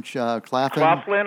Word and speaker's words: Claflin: Claflin.